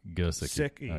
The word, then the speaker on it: Gesicki: Gesicki. Okay. Right. So you pronunciate it G-U-H hyphen. – Sick. – Sick hyphen E. – Got it. – So Gesicki. Next guy, Mark Andrews out of Oklahoma, 6'5", 256, runs a 4.58, had 112 career receptions for 1,765 yards and 22 touchdowns.